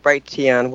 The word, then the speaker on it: Brighteon: It's cleaner, so we're going to be on Brighton instead of Brighteon, however you want to pronounce it.